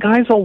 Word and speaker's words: Geisel: Geisel, yeah.